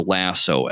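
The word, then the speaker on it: LAHSO: Or lasso, LASSO, is how you would kind of see it if you're looking at it phonetically, like in a NOTAM, which is a notice to airmen.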